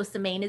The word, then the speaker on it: Semaine: But that's really why we started Semaine, and you pronounced it exactly as we pronounce it, at least, which is not the French way of pronouncing it, but we pronounce it the American way. So Semaine is exactly how we say it, and it does mean the week in French.